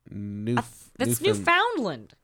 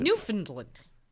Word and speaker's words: Newfoundland: Newfoundland. Yeah. Newfoundland. – Wherever, dude. – That's how they say it, though.